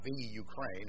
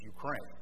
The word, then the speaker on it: Ukraine: It's not the Ukraine, it's Ukraine. I correct myself.